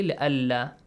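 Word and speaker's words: لئلا: it is actually لألا نون is here merged with لام لألا يكون للناس nasal sound okay لألا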